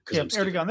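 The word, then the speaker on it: Erdoğan: Erdogan, and I'm mispronouncing his name, I'm sure. – Erdogan, that's good.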